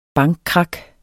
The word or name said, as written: bankkrak